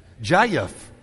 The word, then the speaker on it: Jeff: See, I'm in the land where my name has two syllables to some people. Jayef.